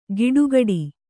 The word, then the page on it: ಗಿಡುಗಡಿ: giḍugaḍi